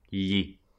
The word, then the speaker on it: gli: To him is an indirect object, and it starts with the letter G, even if we don't actually pronounce it like a G. – Gli. – We've got this G-L-I, and it's pronounced like an L that glides across the top of your mouth.